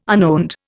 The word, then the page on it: unowned: Indian